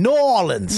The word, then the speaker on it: New Orleans: New Orleans.